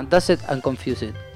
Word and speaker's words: Dazed and Confused: No, esta canción se llama Dazed and Confused.